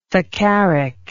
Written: saccharic

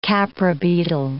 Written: khapra beetle

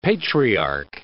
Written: patriarch

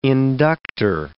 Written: inductor

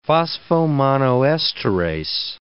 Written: phosphomonoesterase

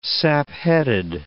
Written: sapheaded

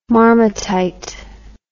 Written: marmatite

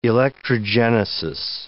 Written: electrogenesis

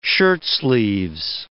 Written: shirtsleeves

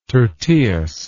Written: tyrtaeus

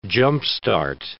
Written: jump-start

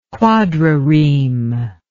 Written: quadrireme

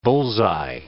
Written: bull's-eye

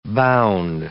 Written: bound

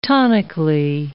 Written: tonically

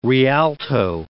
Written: rialto

